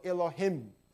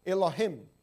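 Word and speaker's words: Elohim (אלהים): 希伯来文的发音叫做 Elohim， Elohim， Elohim， El 是神。你每一次听到 El，刚才我们唱诗歌以马内利，英文怎么说的？ Emmanuel L 是希伯来文， L 是神， Elohim Him，每一次你听到 Him、的时候，就是多数，多数复数。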